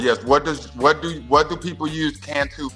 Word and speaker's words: Cantu: It is not an acronym, but there are five letters that I need you to write out to pronounce. C-A-N-T-U. T as in turtle. Cantu. C-A-N-T-U.